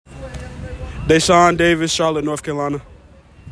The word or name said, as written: Da'Shawn Davis